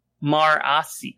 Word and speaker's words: Marasi: But you have to understand, my first read, I'm like, I like Marissi. – Marissi. – Oh, geez. You know I'm going to pronounce things wrong. – Yep. – Mercy. Her name is Mercy. – Wait, what? Marasi.